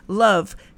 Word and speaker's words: love: Na, na, na palavra amor, por exemplo, love, quando você escuta, se você, se você apenas escutasse a palavra, né, love, sem, sem ver a, a, a ortografia, você ia dizer love, uma sílaba. Mas quando você, como brasileiro, visualiza a palavra love, você fala assim, I-L-O-V-E, e duas sílabas.